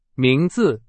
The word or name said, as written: 名字